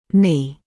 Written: knee

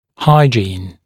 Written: hygiene

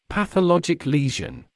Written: pathologic lesion

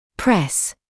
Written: press

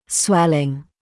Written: swelling

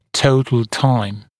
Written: total time

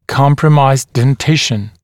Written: compromised dentition

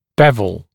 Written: bevel

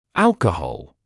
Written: alcohol

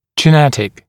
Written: genetic

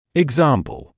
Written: example

